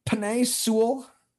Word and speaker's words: Penei Sewell: You got Penei Sewell.